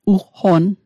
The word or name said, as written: ugqonn